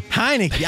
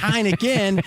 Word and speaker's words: Heinicke: How do you pronounce his name? – Heinicke. – I was thinking Heineken. And I'm like, it couldn't be Heineken.